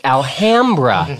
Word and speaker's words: Alhambra: how to pronounce Alhambra. It's Alhambra.